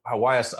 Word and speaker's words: ayahuasca: Uh, oh, ayahuasca.